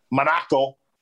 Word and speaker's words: Monaco: I've been to Monaco, or as they pronounce it there, Monaco.